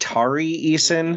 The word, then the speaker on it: Tari Eason: Okay, so your next name, I believe it's pronounced Tari Eason. – Mm-hmm. Yeah. – T-A-R-I Eason, like Tony Eason.